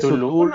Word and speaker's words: Cthulhu: Pues, es Cthulhu.